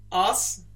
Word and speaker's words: arse: But is that the way you pronounce it? Arse? – Os. You're an os. Yeah, it's the only R, I think, that makes that sound.